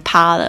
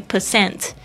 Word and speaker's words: percent: Ninety nine percent 就是九百分之九十九，这个 percent 它之前有那个符号哦，就是不要把再把它念成趴了。 OK，ninety nine percent。